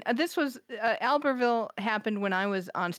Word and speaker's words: Albertville: And we're already going to have an issue because I would pronounce it Albertville. – Oh, yeah. Probably we should be pronouncing it Albertville. – So before January, we have to get, you and I have to decide, are we going to use the English pronunciation or the French pronunciation? – We.